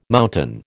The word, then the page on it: mountain: American English